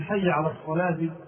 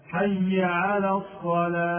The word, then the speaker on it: الصلاة: بعض المؤذنين يمد الألف مدا زائدا ويسقط الهاء من حي على الصلاة فينطقها هكذا: حي على الصلاة، لا ينطق الهاء، حي على الصلاة، صلاة، الصلاة، هذا خطأ، وإنما ينبغي أن يبين الهاء فيقول حي على الصلاة، لابد أن يبين الهاء.